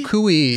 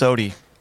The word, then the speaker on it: Suciu: It's pronounced Sodi.